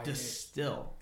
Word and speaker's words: Destihl: And guys, it's spelled D-E-S-T-I-H-L. – Yeah. – Distill. – It's a weird spelling.